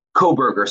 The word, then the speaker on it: Kohberger: The kid, the guy Bryan had been, um, is it Kohlberger? Is that how we're pronouncing his last name? Kohlberger. – It's Kohberger, so no L, Kohberger.